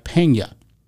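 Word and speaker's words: Peña: And when you have that, the N is not pronounced like an N, it's pronounced like a Y. So when you see the name like Pena, it's spelled P-E-N-A with a tilde above the N.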